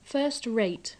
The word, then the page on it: first-rate: English